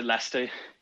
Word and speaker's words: Leicester: Let's go next to from, is it pronounced Leicester? Is that the correct pronunciation? – Yeah, no, it's a Leicester.